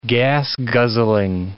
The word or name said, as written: gas-guzzling